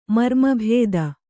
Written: ಮರ್ಮಭೇದ

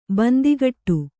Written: ಬೊಂತೆಕಟ್ಟು

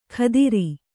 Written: ಖದಿರಿ

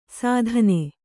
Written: ಸೀತೆನೆ

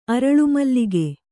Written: ಅರಳುಮಲ್ಲಿಗೆ

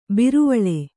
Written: ಬಿರುವಳೆ

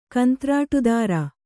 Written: ಕಂತ್ರಾಟುದಾರ

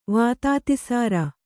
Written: ವಾತಾತಿಸಾರ

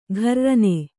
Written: ಕಿರ್ರನೆ

